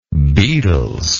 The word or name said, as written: Beatles